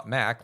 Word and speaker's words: .Mac: A collection of web services was originally called iTools and then later rebranded as the .Mac service, pronounced .Mac like .net, and it included email, online storage, and backup.